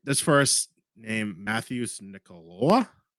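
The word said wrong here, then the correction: Matheus: Oh, that's Mateus, bro. You don't know how to pronounce Mateus? – No, I'm fucking idiot from Boston.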